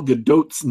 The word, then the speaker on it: Gadot: He reminds me that the last T in Gal Gadot's name is actually pronounced.